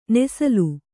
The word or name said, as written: ನೇಸಲ್